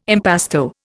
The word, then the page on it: impasto: Indian